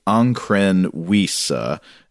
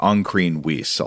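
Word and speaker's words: Ancrene Wisse: And this is called I'm not quite sure how to pronounce this, but I think it is the Ankren Wisa, which is spelled A-N-C-R-E-N-E. And then the second word is W-I-S-S-E, the Ankren Wisa, I'll say.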